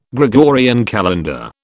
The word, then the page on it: Gregorian calendar: British English